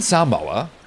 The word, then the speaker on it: Samoa: American Samoa, and yes, that's the correct pronunciation, is an often overlooked collection of islands in the South Pacific.